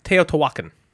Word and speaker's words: Teotihuacan: We've heard pronounced a million different ways, but we pronounce it Teotihuacan.